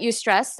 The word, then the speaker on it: eustress: Eustress, or how do you say that? – It's the idea of acute stress. It's short bursts of stress. You can call it eustress.